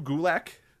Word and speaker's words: Gulak: Drew Gulak. – Okay. Rhymes with attack.